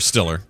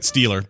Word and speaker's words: Stiller: Anybody who can go toe-to-toe with Jerry Stiller with the anger. – Yeah. – That's pretty impressive. – Or Stiller. But yeah. – Stiller. What'd I say? – Stealer, but it's okay.